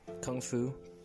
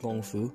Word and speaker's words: Kung Fu: Kung Fu, traditionally pronounced Gong Fu, means hard or skillful work or time spent on hard training.